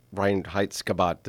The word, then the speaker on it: Reinheitsgebot: So I've heard this Reinheitsgebot. Did I say that right? – Yes.